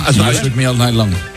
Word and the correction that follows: Shook Me All Night Long: Me cuesta el Shook Me All Night Long.